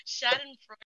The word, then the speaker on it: Schadenfreude: How did you say that? Schadenfreude. – Schadenfreude. – Oh, Schadenfreude.